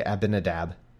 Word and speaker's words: Abinadab: The sons of Abinadab drave the new cart, and they brought it out of the house of Abinadab, which I just want to say like Ahmadinejad every time, so that I get my brain like skips like a record when I start to say Abinadab. I want to put in another syllable.